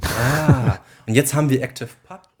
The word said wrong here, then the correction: ActivityPub: und ActivityPub. – Ja. – Mensch. Jetzt, als Nerds hat natürlich direkt falsch ausgesprochen.